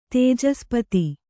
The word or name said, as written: ತೇಜಸ್ಪತಿ